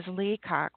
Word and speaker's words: Leacock: And my last name is Leacock, which is like peacock with an L.